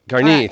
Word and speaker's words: Garnier: Shukrut, and we have a Garnier. I don't know how to... – Shukrut Garnier. – Garnier, thank you. I don't pronounce French very well.